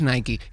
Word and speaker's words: Nike: O Nike, el que venía y decía, no es Nike, es Nike.